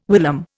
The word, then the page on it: whilom: Indian